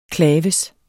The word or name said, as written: claves